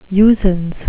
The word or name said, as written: usance